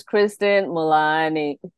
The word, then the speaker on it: Christine Mullaney: That was Christine Mullaney.